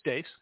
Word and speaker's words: Deace: Yeah, it's Dace. It's Dace. – Yeah, okay. I always say Dace because it's spelled D-E-A-C-E.